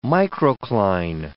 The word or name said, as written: microcline